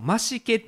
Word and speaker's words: 増毛: これ増毛と書いてあのマシケって読むらしいんですけど、マシケ町でカツラ疑惑否定、私は大丈夫、地毛アピールと。あの北海道にこのマシケ